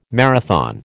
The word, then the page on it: marathon: American English